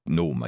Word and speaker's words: GNOME: It's spelled genome, or gnome, I guess, but it's pronounced GNOME, according to Mark here.